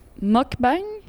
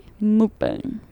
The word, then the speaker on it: mukbang: J'ai lu Mokbang. Mukbang.